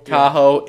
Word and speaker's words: Tahoe: We have NHL, another A++ marketing job by the NHL, which they are notoriously bad at, an outside game in Tahoe. I think that's pronounced that way. – Tahoe. – Yeah.